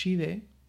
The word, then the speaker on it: Sidhe: Por lo tanto, aseguraban que los Side o Sidhe, porque esto es gaélico, es un poquito complicado de pronunciar, es como se llamaban a los pueblos féricos, también celebraban el Samhain.